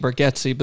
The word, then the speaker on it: Bargatze: Now Nate Berg, his name is spelled Bargatze. He says it Nate Bergetzi, but then he admittedly says, "I'm pretty sure my family says it wrong."